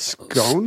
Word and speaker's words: Scone: The Stone of Scone. – How did we say? We also said scone? – Stone of Scone, because that makes sense, because it rhymes. – Stone of Scone? – And we say S-C-O-N-E is a scone.